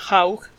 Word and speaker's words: Hough: Eh, este libro lo escribe Susan Haug, se escribe H-O-U-G-H,